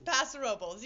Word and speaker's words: Paso Robles: It's Paso Robles, yeah.